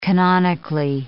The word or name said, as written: canonically